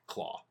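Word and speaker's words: Klaue: Is it pronounced Claw? – Claw. – Claw. – Yeah.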